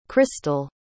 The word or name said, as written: christal